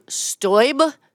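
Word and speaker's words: Steube: Representative William Stube, that is how it's pronounced.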